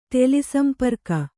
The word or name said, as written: ಟೆಲಿಸಂಪರ್ಕ